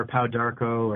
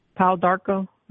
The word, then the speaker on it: pau d'arco: Am I saying that right? Paul DiArco? – I've heard it pronounced that way, or Paul Darko, or Pau Darko. Okay, mm. yeah, that sounds better. It kind of flows off your tongue a little better.